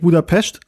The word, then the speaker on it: Budapest: Wir sagen ja eigentlich, wir können ja sagen, eigentlich Budapest, so wird es eigentlich ausgesprochen.